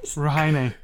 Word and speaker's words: Rujaine: R-U-J-A-I-N-E. – Say again? – Rahine.